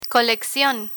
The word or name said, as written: coleccion